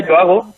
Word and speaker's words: Joago: como bueno, se, se pronuncia Yoago, pero se escribe Joago.